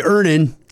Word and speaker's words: Earnin: Earnin. E-A-R-N-I-N is pronounced Earnin. I don't know how else you would pronounce it, but they really want it clear that that's how to pronounce it.